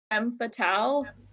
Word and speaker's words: femme fatale: Is it Femme Fatale? Did I say that right? – Mm-hmm.